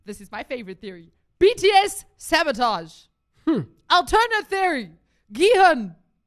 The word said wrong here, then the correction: Gi-hun: I didn't say his name right. Alternate theory. Now I'm going to stop everything. Gi-hun.